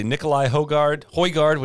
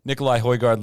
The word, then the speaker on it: Nikolai Højgaard: Lastly, Nikolai Højgaard, which I learned the pronunciation actually is, wins on the European Tour.